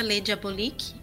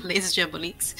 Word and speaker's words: Les Diaboliques: Eu tô no Twitter e no Instagram, e eu tenho uma página chamada Revista Les Diaboliques, pronuncia no singular, mas escreve no plural, Les Diaboliques.